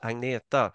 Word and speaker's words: Agneta: You know, most people outside Sweden can't even pronounce Agneta, which is Agneta is the exactly. It's the correct name. I don't think I've heard anyone from an English speaking country ever pronounce that name correctly. However hard they try, it's a sound there that you don't have.